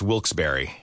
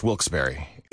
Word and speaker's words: Wilkes-Barre: Now, I had heard that it was supposed to be pronounced Wilkes-Barre. Wilkes-Barre Scranton's 97.9X. The only way to pronounce Wilkes-Barre.